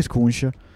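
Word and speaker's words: Skujiņš: Poi vanno via però Rex, Oldani e Ballerini, a cui si unisce anche Skunsch. – Bravissimo, vedi come hai imparato la pronuncia di Skunsch subito.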